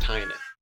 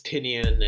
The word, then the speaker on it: Tynan: Also, the name's pronounced Tynan. But obviously he gets Tinian and Tiny Onion, all these other variations pretty often.